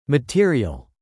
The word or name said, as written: material